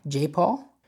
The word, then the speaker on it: Jaipal: Jaipal, J-A-I, Jaipal, performed for the first time at Coachella this year, and his bass player was Rocco Palladino, son of session bassist Pino Palladino.